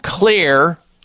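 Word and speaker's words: Xlear: The one that I like at this time of year is called Clear Rescue Nasal Spray. Clear is spelled with an X, X-L-E-A-R. The X stands for xylitol.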